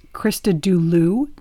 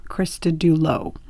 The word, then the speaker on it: Christodoulou: So her name is pronounced Christodoulou, not Christodoulou.